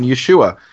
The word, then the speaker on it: Yeshua: through the Second Temple pronunciation Yeshua.